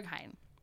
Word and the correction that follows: Berghain: Berghain and Kit Kat Club.